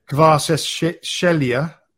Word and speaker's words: Kvaratskhelia: Kvaratskhelia. – Kvaratskhelia.